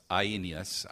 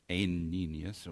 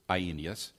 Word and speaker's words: Aeneas: I looked up the pronunciation of that. It's Aeneas. I thought it was Aeneas, but it's Aeneas.